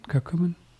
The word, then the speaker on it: curcumin: Now, how do you say it? Curcumin? Curcumin? – Curcumin, and that's not the active ingredient.